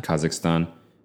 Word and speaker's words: Kazakhstan: This is really just an aside, but didn't Kazakhstan, am I even pronouncing it right? – Kazakhstan.